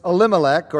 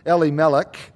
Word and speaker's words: Elimelech: Verse 2, we read, the name of the man was Elimelech, or more a Hebrew pronunciation, Elimelech.